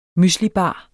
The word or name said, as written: myslibar